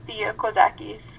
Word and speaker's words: Thea Kozakis: My full name, as people struggle to pronounce, is Thea Kozakis.